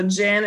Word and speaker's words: Gin: So Gin is like gin and tonic.